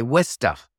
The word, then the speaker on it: Worcester: Yeah, it's, well, if you lived up there, you'd say Worcester.